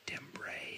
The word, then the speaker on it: timbre: spelled T-I-M-B-R-E. Looks like timbre, pronounced timbre.